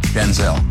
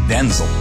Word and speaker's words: Denzel: My name's not pronounced Denzel. It's pronounced Denzel.